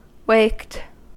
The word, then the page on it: waked: American English